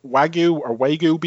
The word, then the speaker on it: Wagyu: Is it Wagyu beef? Is that how you pronounce it? – Yes.